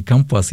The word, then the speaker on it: компас: Иногда мы знали такие случаи, и все мы вспоминаем, кто-то смотрел, кто-то там читал «Дети капитана Гранта», когда топор, подложенный под компас, вот так я проверял ударение, правильное ударение компас, если это такой большой прибор на корабле, показывал не то направление.